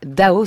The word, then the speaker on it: DAU: Pas Dao Étienne, parce que ça se prononce comme ça, le chanteur, mais Do, D-A-U, prononcé Dao, ce projet artistique qui doit son nom à Lev Landau, physicien soviétique nobelisé en 1962.